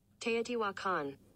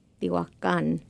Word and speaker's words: Teotihuacan: Teotihuacan. – Ah, yes. I am not good at this. – Teotihuacan. – Teotihuacan. That sounds a little Americanized, but I'll take it.